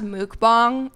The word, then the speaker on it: mukbang: So, so what we're gonna do is something she said was called a mukbang. – It's pronounced mukbang. It's like a. I think it's Korean.